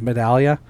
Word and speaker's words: Medaglia: My last name is Medallia, but it's spelt with a G.